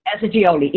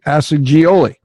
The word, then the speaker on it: Assagioli: Roberto Assagioli. – Assagioli, Italian man. – With a G, Assagioli.